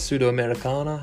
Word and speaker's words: Sudamericana: I've seen Independiente play in the Copa Sudamerica, I think it was, Sudamericana, how you pronounce it, as the underdog, and they walked away with the win.